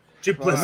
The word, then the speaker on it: Du Plessis: Middle Middleweight bout Robert Whittaker versus Dricus eh, Felicis. Can't pronounce the last name, so excuse me, ladies and gentlemen. – Du Plessis. – Right.